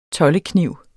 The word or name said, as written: tollekniv